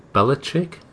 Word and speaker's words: Belichick: the New England Patriots coach, Bill Belichick, I'm saying that right? – Belichick's right, yeah.